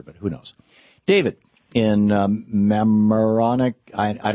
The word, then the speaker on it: Mamaroneck: Mamaroneck. – Thank you. Mamaroneck.